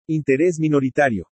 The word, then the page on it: INTERÉS MINORITARIO: Spanish